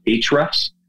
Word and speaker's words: Ahrefs: Another one that actually will tell you exact specific things to look at, including some of the visible things, is a site called Ahrefs, which is Ahrefs. – Okay. – So it looks like Ahrefs, but it's Ahrefs is how they say it.